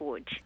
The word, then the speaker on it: Łódź: That's written Lodz, but it's pronounced Łódź.